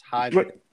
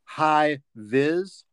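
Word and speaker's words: High Vis: High Viz. – Say it again, one more time. – High Viz. – Spell it. – HIGH. And then the second word is Viz, VIS.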